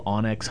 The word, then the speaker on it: onX: A lot of our technicians have an app called Onyx Hunt. I don't know if you guys have heard of it. Mm-hmm. It's spelled O-N-X Hunt.